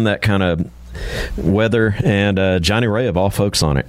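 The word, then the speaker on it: Johnny Rea: How do you say it, Jonathan? – Johnny Ray. – There you go. I interrupted it. Try it again. – Johnny Ray.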